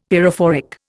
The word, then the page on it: pyrophoric: Indian